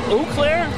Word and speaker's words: Eau Claire: So Nicole, N-I-C-O-L-E, Blair, A-U-C-L-A-I-R. – Okay, so it's Eau Claire? – Yes, Eau Claire.